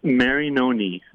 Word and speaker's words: Marinoni: Marinoni.